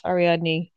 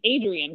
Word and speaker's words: Ariadne: Because they say, yeah, Ariadne. – And I kept when I read it, I think Adrian because it looks like Adrian to me.